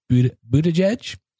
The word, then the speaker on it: Buttigieg: If you haven't heard of them before, they've been in the news recently because of the presidential candidate, Pete, and I'm going to butcher his last name, Buttigieg. Is that a pretty good job? – I think that was a good job, yeah.